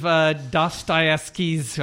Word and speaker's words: Dostoevsky's: So y'all are doing a version of Dostoevsky's, I can't pronounce.